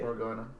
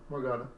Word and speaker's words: Morgana: How do you say it? – Morgana. Morgana. – Like Morgan. Morgan A. – Yeah. It's like Morgan with an A.